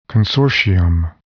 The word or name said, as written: consortium